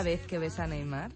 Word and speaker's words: Neymar: No, el único fallo que tiene Neymar yo creo, Neymar, lo digo bien.